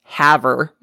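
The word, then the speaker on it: Havre: You may or may not have caught it, but in the intro, I said that Rochelle was from Harve, Montana, when in actuality, it is pronounced Haver, Montana.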